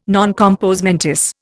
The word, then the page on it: non compos mentis: Indian